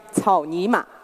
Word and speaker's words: 草泥马: Read after me, 草泥马. Good. 草泥马 is a near homophone of, which means that it pronounces the same as 操你妈, which literally means fuck your mother.